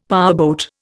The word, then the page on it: powerboat: Indian